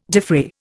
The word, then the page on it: defray: Indian